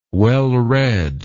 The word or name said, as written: wellread